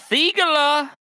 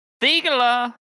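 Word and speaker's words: Theegala: I mean, how about Thigala? Thigala. – The God, man. Yeah. – I want to say his name. It sounds like the, you know, the Ricola cough drops.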